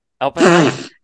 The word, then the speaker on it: Oppenheimer: Oppenheimer.